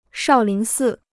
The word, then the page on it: 少林寺: shào lín sì